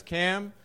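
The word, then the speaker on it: Kam: My name is Cam with a K.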